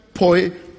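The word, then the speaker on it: poiema: It's P-O-I-E-M-A. And you pronounce that poiame. Poiame. It's where we get the word poem.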